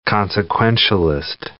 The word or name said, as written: consequentialist